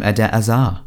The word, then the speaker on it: Eden Hazard: And then, obviously, on the left, we should play 100% Eden Hazard, or as he said it's pronounced, Eden Hazard. He actually said that in the uh, Chelsea reward evening. Uh, uh, so I'm just going to call him Ede Azar.